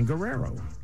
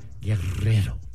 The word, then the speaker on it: Guerrero: I'm Dan Guerrero, or if you can roll your R's, Guerrero.